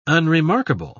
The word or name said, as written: unremarkable